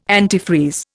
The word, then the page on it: antifreeze: Indian